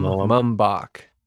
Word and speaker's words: Mumbach: It's Mum, like quiet, you know, like be Mum. Mumbach.